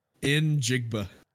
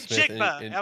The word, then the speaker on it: Njigba: Najigba, is that right? – Njigba. Jackson Smith and Jigba. – Jackson Njigba.